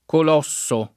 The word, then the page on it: colosso: italiana